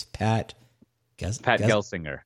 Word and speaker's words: Gelsinger: You know, I watched, how do you say the CEO of VMware's name correctly? It's Pat Gelsinger. Gelsinger. No, I was about to say it wrong.